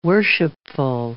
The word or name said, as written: worshipful